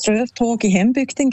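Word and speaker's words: Strövtåg i hembygden: Ich finde, du hast die Aussprache super hergebracht. Das Lied heisst Ströftag in Hembügden, genau.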